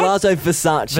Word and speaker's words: Palazzo Versace: Did I say that right? – Palazzo Versace. – Versace, yep.